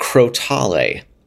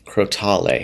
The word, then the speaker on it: Crotale: And he talked to a classical percussion specialist there who confirmed that it is pronounced Crotale. Crotale. – Crotale. – So, yeah, Crotale. So, Crotale, Crotales. That is the pronunciation.